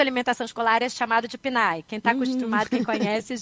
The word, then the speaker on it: PNAE: Eu ia dizer PNAE agroecológico, mas eu não sei se está certo, correta a pronúncia. – Tá correto, é porque PNAE, PNAE é o nome do Programa de Alimentação Escolar do Brasil.